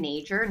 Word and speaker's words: Nager: My name is Megan Nager.